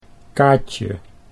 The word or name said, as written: Kaatje